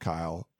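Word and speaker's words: Kiel: And then a couple more, Kyle McDonald, it's K-I-E-L, but it's just pronounced Kyle.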